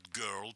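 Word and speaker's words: Görl: Известный товарищ по имени Роберт Герл, пишется О с двумя точечками, как Motorhead.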